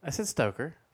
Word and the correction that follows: Strucker: Stalker. – Stoker. – What is it, then? – Strucker. – Strucker. Strucker.